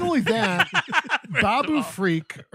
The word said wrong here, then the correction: Babu Frick: or whatever his fucking Babu Frick.